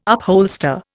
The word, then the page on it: upholster: Indian